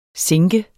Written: sinke

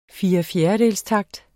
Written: firefjerdedelstakt

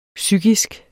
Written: psykisk

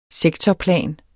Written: sektorplan

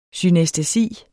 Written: synæstesi

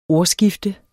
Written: ordskifte

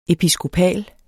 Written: EPISKOPAL

